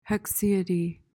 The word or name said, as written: haecceity